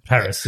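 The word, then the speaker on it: Parris: Because literally, little Akira Akbar, she's not going to be able to play the adult, but then Teyonah Parrott. I said that right, yeah. – Paris, yeah.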